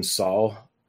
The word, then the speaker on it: Sol: So in the dub, they pronounce his name Sol. And it sounds a lot like Saw Gerrera from Star Wars, but he did get a little bit of the L at the end.